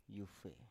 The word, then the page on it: yufe: Biak